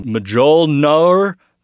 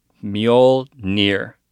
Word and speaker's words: Mjolnir: I was saying Mjolnir, and it's actually Mjolnir.